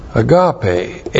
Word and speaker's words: agape: Agape, A-G-A-P, long E.